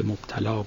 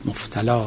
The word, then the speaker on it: مبتلا: به قفل می گفت، قلف به مبتلا گفته بود، مفتلا و امثال اون.